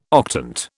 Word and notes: octant: British English

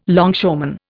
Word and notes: longshoreman: Indian

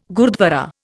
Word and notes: gurdwara: Indian

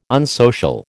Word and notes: unsocial: American English

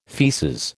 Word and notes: faeces: American English

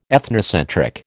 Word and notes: ethnocentric: American English

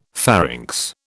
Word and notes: pharynx: British English

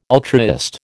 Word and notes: ultraist: American English

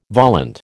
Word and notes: volant: American English

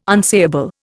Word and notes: unsayable: Indian

